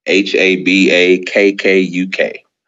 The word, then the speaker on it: Habakkuk: minor prophet, H A B A K K U K, mm.